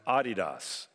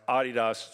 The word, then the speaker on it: Adidas: In London, it's pronounced Adidas. In Europe, it's pronounced Adidas. It's a German company. It's not Adidas, just preparing you. So if you ever go there, you won't sound ignorant.